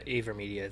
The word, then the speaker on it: AVerMedia: It's pretty high, and I know you can get the cheaper Elgatos for like $150 or even, how do you say it? Avermedia? – Yeah, Avermedia, I think.